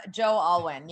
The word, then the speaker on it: Joe Alwyn: How do you say his name? Do you know? – Joe Alwyn. Yeah.